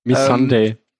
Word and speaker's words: Missandei: Zum Beispiel Grauer Wurm und Missandei, ich hoffe, ich habe das richtig ausgesprochen. – Missandei.